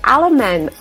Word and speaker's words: Allemann: I've actually got Andrew Allemann on the line today.